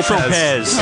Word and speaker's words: Saint-Tropez: If you're pronouncing it in the correct French, then it would be Tropez. – And it's French. – Yeah. – So it's not spelled Tropez.